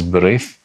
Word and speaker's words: B'rith: because that is the correct pronunciation. The word is B'rith in Hebrew, and that is how it was transliterated very correctly.